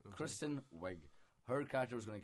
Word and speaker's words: Kristen: Like, but yeah, she's like, Kirsten Wiig is like the one who sort of thinks she has it more together. How do you pronounce that? – It's Kristen.